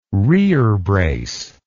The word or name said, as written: rerebrace